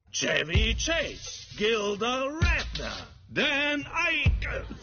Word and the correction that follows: Aykroyd: wie zum Henker spricht man den aus? – Aykroyd.